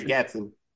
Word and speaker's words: Frank Gatson: Frank Gatson. – Gatson, there we go. I always get his name wrong because of all the T's and S's in the middle.